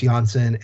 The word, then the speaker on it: Johnsson: but you should also pay attention to Andreas Johnsson and Jesper Bratt. Johnson, as I just messed up his name because now he's a hard J and not a soft J.